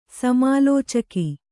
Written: ಸಮಾಲೋಚಕ

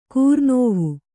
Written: ಕೂರ್ನೋವು

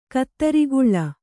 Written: ಕುದುರುಗೊಳ್

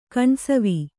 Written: ಕಣ್ಸವಿ

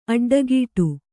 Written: ಅಡ್ಡಕಟ್ಟು

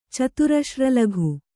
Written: ಚತುರಶ್ರಲಘು

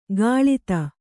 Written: ಕಳಾದ

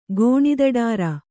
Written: ಗೋಣಿದಡಾರ

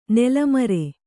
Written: ನೆಲೆಮುರಿ

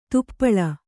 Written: ದೀಪಾಳಿ

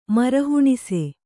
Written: ಮರಹುಣಸೆ

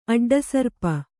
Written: ಅಡ್ಡಸರ್ಪ

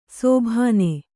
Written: ಸೋಭಾನೆ

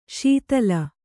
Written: ಸ್ಥಾಲಿ

